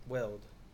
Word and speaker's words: Weld: K-A-S-I-C-H. – And Bill Wild. Weld.